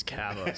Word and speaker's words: Kava: I'm like, well, no, that's actually, they say their last name wrong. It's Kava.